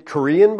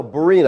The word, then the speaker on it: Berean: I often get Korean Bibles. No, Berean. I have to spell it every time. Berean, B-E-R-E-A-N, Berean Bible Church.